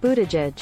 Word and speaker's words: Buttigieg: Pete Buttigieg. Pete Buttigieg. – Okay. I almost got it right. Almost. Okay. It counts for something. Hey, you know, those network clowns, they don't get it right either. Pete Buttigieg.